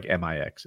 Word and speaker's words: Mx: It's pronounced like MIX. It's mix.